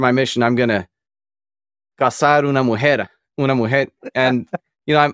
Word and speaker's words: cazar: But the word casar, C-A-S-A-R. Yeah. Or more appropriately, casarse is to get married. But if you change the S to a Z, in Spain it's pronounced differently. It'd be cazar. But in the Americas, it's cazar. It sounds the same, but it means to hunt.